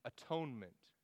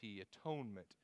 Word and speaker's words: atonement: That theological word is the atonement, the atonement. If you're taking notes, it's spelled A-T-O-N-E-M-E-N-T, atonement. And it comes from a kind of a conglomeration of English words. It's at-one-ment.